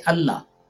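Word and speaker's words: Allah (الله): Now, that means that if it's Al-Illah, both the words should be separately pronounced. But we don't. We say Allah.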